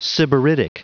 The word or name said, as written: sybaritic